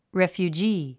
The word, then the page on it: refugee: rèfjudʒíː; レェフュジィ